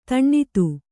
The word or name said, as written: ಧನದ